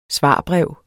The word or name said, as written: svarbrev